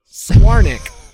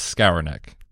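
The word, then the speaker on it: Skowronek: Swarnik. – Nope. I believe the common pronunciation out there is Skoronek. – Skoronek. – Skoronek. Skoronek is how I've heard it pronounced.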